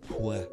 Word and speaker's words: Puebla: You said they won in Pueblo, right? – No, I definitely butchered that word. It wasn't Pueblo. Pueblo. Puebla.